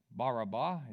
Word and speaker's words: Barabbas: Barabbas is how you really want to pronounce that.